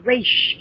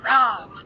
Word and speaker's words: Ra's: It's just not like, it's a common misconception that my father's name is actually pronounced Raish. It's not Roz, but that's okay.